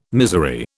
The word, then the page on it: misery: British English